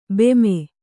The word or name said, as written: ಭೀಮ